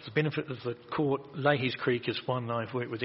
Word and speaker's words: Lahey's: Leahy's Creek. – It's spelled L-A-H-E-Y-S.